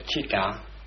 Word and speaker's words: citta: We call it citta. We might spell it citta, and put an accent on it to remind us that that is pronounced like ch.